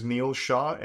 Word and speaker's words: Neil Shah: Yeah, so I think the full name is Neil Shah. And if you want to pronounce it, N-E-I-L. And the last name is S-H-A-H.